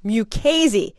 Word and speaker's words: Mukasey: as CNN repeats Mukasey, Mukasey, Mukasey all morning, it's pronounced Mukasey.